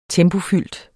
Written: tempofyldt

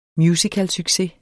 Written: musicalsucces

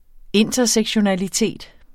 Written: intersektionalitet

